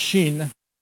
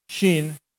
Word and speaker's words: shin: And it means, it's pronounced shin, shin. And it actually, to a Jewish person, is emblematic of El Shaddai, El God, Shaddai Almighty, God Almighty.